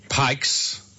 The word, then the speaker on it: Pykes: Ring Pike's Nurseries. – What nurseries? – Pike's. – Pike's. – Yes. P-Y-K-E-S. – P-I-A.